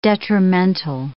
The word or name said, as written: detrimental